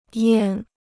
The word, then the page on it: 验: yàn